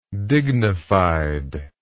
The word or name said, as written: dignifiedness